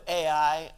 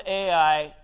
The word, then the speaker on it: Ai: The people of Ai, or A as the Hebrew pronunciation may more accurately be, but I'm going to stick with Ai because, you know, I feel like if I say A, you're going to think Fonzie is preaching to you today.